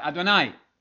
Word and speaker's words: Adonai: It rhymes with El Shaddai, and he almost to sing you the song, da da da da da da da da da, and everybody knows El Shaddai. You remember, okay, El Shaddai rhymes with Adonai.